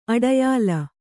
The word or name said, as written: ಅಡಯಾಲ